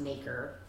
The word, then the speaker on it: Noecker: My last name is NOECKER, but it's pronounced Naker. Like it rhymes with Baker.